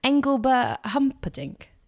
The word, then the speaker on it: Engelbert Humperdinck: And, Engelbert Humperdinck. Is that right? Is that how I said that right? – That is right, yeah.